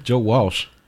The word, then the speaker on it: Walsh: Joe Walsh. – That's what I said. – You said Joe Welsh. – What's the difference? – It's a big difference.